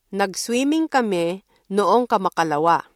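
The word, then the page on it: kamakalawa: Filipino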